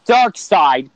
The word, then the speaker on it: Darkseid: Well, it's pronounced Darkseid because the E and the I. Einstein mm-hmm. and Stein.